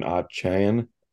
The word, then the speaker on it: Achane: It's Devin Achane. – Okay. – Achane. It's not Achane. He came out and said that he wants people to pronounce it Achane.